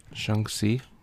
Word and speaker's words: Shang-Chi: I think my son just corrected me. How do you say it? – Shang-Chi.